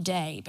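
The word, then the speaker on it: day (δεῖ): So if you look at my notes, it's spelled D-E-I, but I spelled it day, because D-A-Y, that's how it's pronounced.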